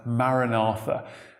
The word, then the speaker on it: Maranatha: Our Lord, come, or O Lord, come, is pronounced from the original text as Maranatha.